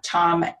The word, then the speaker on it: Thom: And I'll put a link to your Facebook profile because his name is spelled T-H-O-M, Tom Gilbert. So it's a little different.